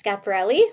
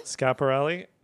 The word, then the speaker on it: Schiaparelli: Yeah, I mean, Schiaparelli, is that how you pronounce? – Yes, Scaparelli. – Schiaparelli.